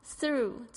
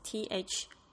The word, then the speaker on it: through: step STEP through 这个字我们已经讲过好多次了，它就有穿过的意思。 THROUGH 注意一下 TH 的发音 th 的声音 through，然后呢 GH 这边没有发音 through。